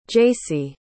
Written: jaci

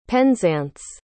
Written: penzance

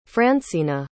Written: francena

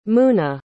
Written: muna

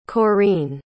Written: Corine

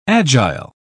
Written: agile

